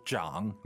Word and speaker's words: 长: Is that the same character as chang? – 是的,但是发音不一样. – Okay, so chang meaning long, but this is zhang meaning to grow? – 没错.